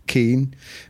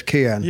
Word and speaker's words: Kian: The Irish pronunciation is Keen, but he says Kian.